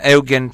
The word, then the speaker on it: Eugen: The last man on the moon, Eugen Cernan, or how you pronounce it in English, Eugene Cernan, is Eugen Cernan.